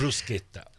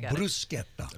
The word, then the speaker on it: bruschetta: Bruschetta. – And how about the gnocchi? – Gnocchi. – Gnocchi. I got. Okay. Did you say bruschetta? – Bruschetta. – Oh, accent on the first syllable.